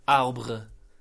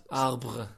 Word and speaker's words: arbre: Arbre. – Arbre. – Yeah, so... – Is it got a... – Arbre, yeah, arbre. – Arbre. – So it's A-R-B-R-E, that's how you spell it. – A-R-B-R-E. – A-R-B-R-E. How do? – A-R-B-R-E. – A-R-B-R-E.